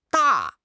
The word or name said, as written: da4